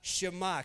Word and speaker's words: Shema: The first word of the Shema is hear or listen, which in Hebrew is pronounced as Shema.